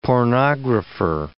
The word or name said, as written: pornographer